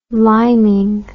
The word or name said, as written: liming